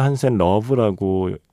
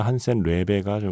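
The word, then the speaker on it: Hansen-Løve: 보통 우리가 미아 한센 러브라고 발음을 했는데 원래 발음은 미아 한센 레베가 좀 더 좀 가까운 발음인가 봐요.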